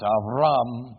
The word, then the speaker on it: Avram: It's you, it's Avram, but we're going to add a few syllables, change the pronunciation, it's Abraham.